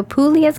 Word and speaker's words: Puglia: P-U-G-L-I-A. – Okay, so but it's Puglia, so Puglia. The G is silent? – Uh-huh.